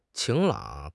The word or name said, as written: 晴朗